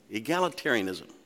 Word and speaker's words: Egalitarianism: How do you say that? Egalitarianism. There you go. Egalitarianism.